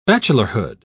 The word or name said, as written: bachelorhood